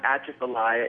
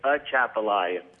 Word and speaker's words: Atchafalaya: It's not Atchafalaya, it's Atchafalaya.